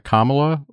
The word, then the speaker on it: Kamala: Is that Kamala or is it still Biden? – Thank you for not being racist in your pronunciation there.